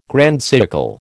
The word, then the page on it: grand siècle: American English